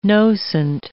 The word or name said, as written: nocent